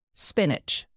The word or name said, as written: spinach